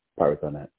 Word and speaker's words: Pirathonite: the Pirathonite, if I'm if I'm saying that right. – Tron. Pirathonite, yes, yes sir.